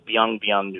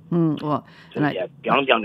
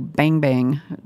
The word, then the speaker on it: Biang: I think we've distorted them here in this country into Bang Bang noodles because of the table slap sound and B-I-A-N-G, Bang Biang, being pronounced Bang.